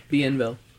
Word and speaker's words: Bienville: And it's on the corner of Bourbon and, I'm going to say this wrong because I know it's French, Bienville Streets. – Bienville. – I said it right? – Bienville.